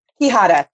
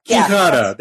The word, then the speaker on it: Quijada: Quijada. – Quijada.